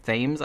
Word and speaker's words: Thames: And it centers mostly around a boy named Finney Blake, who's played by Mason Thames, I guess is how you pronounce his last name, even though I always want to say Thames, because that's how the river is spelled.